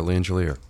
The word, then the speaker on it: Langelier: We're talking with Kat Perry and Sergeant Victoria Langelier. Soft G. Got it.